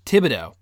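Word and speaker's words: Thibodeaux: I was going to say, how do you pronounce his last name? – Thibodeau. – Is that true? Is that accurate? – That's how I've heard it pronounced.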